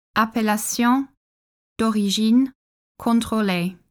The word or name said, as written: Appellation d’origine contrôlée